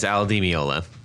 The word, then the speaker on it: Al Di Meola: For the record, it's Al Di Meola.